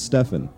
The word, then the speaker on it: Stefan: one that's easier for most people to actually pronounce because for some reason when I tell people like, "Oh yeah, my name is Stefan," they instantly start going Stephen.